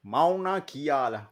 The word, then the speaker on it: Mauna Kea: The tallest mountain is I'm not sure if I'm pronouncing it right, but it's Mauna Kea in Hawaii. – Mauna Kea.